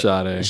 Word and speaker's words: Sade: Or maybe it's Sade? – It's Sade. – Sade, excuse me. I say it incorrectly.